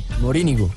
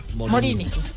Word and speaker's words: Morínigo: En partido donde se disputa la Copa General Morínigo, ¿cómo se dice? – Morínigo. – Morínigo.